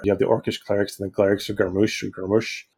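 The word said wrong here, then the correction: Gruumsh: I can never say that properly. – Grumsh. – Yeah.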